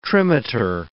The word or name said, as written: trimeter